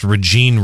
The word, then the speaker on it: Rejeane: Her name is Regine, as John mentioned, pronounced Regine Redman, it's kind of unusual spelling, R-E-J-E-A-N-E.